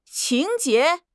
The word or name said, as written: 情节